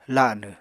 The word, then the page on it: lanw: apatani